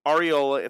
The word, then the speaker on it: Areola: I saw a penalty, Areola, if I'm saying that correctly, sure.